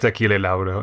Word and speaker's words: Achille Lauro: How do you say his name? Achille? – I think it's Achille Lauro, yeah.